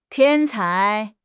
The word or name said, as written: 天才